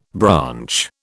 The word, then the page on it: branch: British English